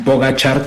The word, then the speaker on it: Pogačar: que termine en tercer lugar detrás de este esloveno Pogačar, que ya no sabía que se pronunciaba así, qué bueno que ustedes me enseñaron a pronunciarlo.